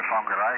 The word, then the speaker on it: Whangarei: Auckland is much easier to say than Whangarei, which is the place we come from.